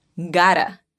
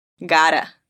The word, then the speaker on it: gotta: Y aunque se escribe con la doble T, termina sonando como una R suave de español. Dilo conmigo. Gotta. Gotta.